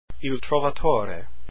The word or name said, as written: Trovatore